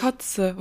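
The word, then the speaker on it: cozze: Also so C-O-Z-E geschrieben, glaube ich. Kotze. Wird das auch so ja. ausgesprochen? – Ja.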